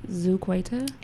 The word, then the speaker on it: Zuquaita: I am Zuquaita. – And I'm Timmy. I love how you say Zuquaita. – I have to say my name phonetically because people get it wrong.